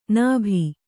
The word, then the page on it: ನಭಿ: nabhi